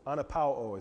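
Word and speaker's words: Anapao (ἀναπαύω): And, and that word rest in the Greek is anapao. Anapao is how you say it. I, I listened to an audio clip so I could say that right.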